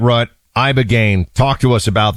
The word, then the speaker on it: Ibogaine: Ibogaine. Remember the name. Is that pronounced relatively properly, would you say? – Yes. – I don't want to get it wrong. Ibogaine, because it's so important, and experienced an 80 to 90% reduction in symptoms of depression and anxiety within 1 month.